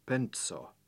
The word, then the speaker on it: penso: Penso, a penny, is spelt with the letter C after the N, which means that it's pronounced with a TS sound, penso. Try and keep this distinct from the simple N plus S sequence in the word penso, a thought, which is spelt with an S. Penso, penny, versus penso, thought.